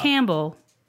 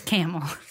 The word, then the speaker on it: Campbell: spelled Campbell, pronounced Camel. – Don't call it Campbell. – People don't call it off. Campbell. They'll beat you up. It's Camel.